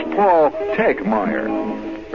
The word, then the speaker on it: Tegmeyer: He says the second T is silent. So it's Paul Tegmeyer. Very silent T in there.